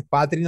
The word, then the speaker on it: Patrick: Es Patrick nomás, o sea, el C y K al final.